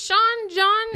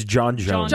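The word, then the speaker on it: J'onn J'onzz: Is it Sean John? – It's John Jones.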